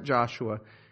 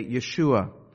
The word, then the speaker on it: Yeshua: Now, in Hebrew, there is no letter that makes the J sound. And so if you were to say it straight from Hebrew, we wouldn't pronounce it Joshua. We would say Yeshua.